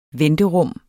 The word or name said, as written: venterum